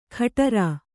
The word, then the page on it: ಖಟರಾ: Kannada